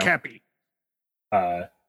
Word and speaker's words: Topkapi: Peter Ustinov wins Best Supporting Actor for Topkapi. I don't know how to pronounce that, and I'm going to stop butchering it now. – Topkapi.